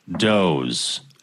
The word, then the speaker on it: Dawes: Looking forward to the Da Wez podcast. – I think that's pronounced Doze. – Oh, is it? – I think so.